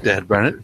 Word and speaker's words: Dad Burnett: Not now, Dad Burnett. What's up? – Dad Burnett? – Can you spell that? – D-A-D Burnett.